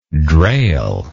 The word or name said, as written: drail